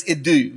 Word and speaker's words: idou: The Greek word there is idou. You would spell it in English, I-D-O-U.